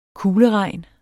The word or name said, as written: kugleregn